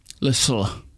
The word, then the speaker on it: Lisieux: Lisieux.